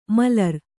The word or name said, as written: ಮಲರ್